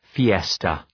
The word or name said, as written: fiesta